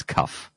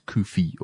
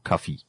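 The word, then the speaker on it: Cuffe: Well, technically, if you read his name, you would read it as Cuff. But how it's pronounced, or seemingly pronounced, or should be pronounced, is Koofy or Cuffy.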